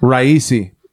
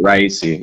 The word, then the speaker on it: Raisi: Raisi, yeah. – Definitely Raisi, yeah.